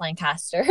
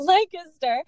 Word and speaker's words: Lancaster: It's Lancaster, Pennsylvania, not pronounced Lancaster, but Lancaster.